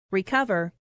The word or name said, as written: recover